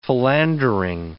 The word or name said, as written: philandering